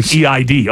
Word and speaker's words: Eid: Eid. – Eid, you're correct.